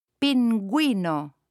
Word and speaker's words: pingüino: If there are two little dots on top of the U, then the U is pronounced. Listen and repeat. – Pingüino.